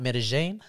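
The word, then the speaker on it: Medellín: And it's pronounced Medellin.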